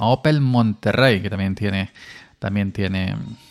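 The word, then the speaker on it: Monterey: llegó también MacOS Monterrey, que aunque se escribe em, con una R, se pronuncia como si tuviera dos, MacOS Monterrey,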